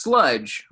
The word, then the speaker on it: Slugdge: It's, so like it's Slugdge, right? I, I, guess that's how you pronounce it. It looks, but there's a G before the D, so it's like the word slug mixed in with sludge.